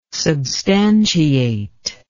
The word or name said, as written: substantiate